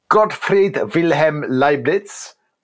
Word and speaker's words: Gottfried Wilhelm Leibniz: So Gottfried Wilhelm Leibniz. Have I said that right, Angela? – Near enough, yeah.